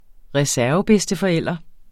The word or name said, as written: reservebedsteforælder